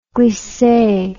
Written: glisse

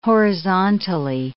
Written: horizontally

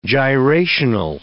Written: gyrational

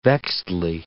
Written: vexedly

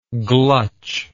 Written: glutch

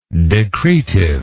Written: decretively

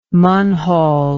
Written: munhall

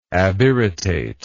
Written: abirritate

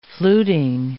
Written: fluting

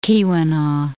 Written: Keweenaw Peninsula

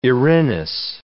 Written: Erinys

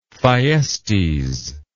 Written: thyestes

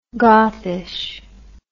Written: gothish

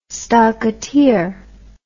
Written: stockateer

